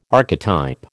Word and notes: archetype: American English

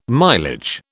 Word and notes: mileage: British English

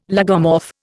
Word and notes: lagomorph: Indian

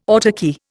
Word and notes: autarky: Indian

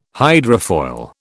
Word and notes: hydrofoil: British English

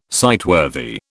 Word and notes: sightworthy: British English

